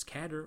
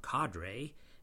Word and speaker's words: cadre: You can pronounce this word either way as cadre or cadre, and no dictionary will say you're wrong.